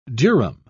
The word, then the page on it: durum: British English